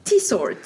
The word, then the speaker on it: Tietsort: Et en fait, pas du tout, on prononce ça Tit Sort. Voilà.